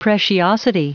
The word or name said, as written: preciosity